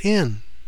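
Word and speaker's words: in (ἐν): Well, the word in is translated from the Greek word en or epsilon nu, and it's pronounced in.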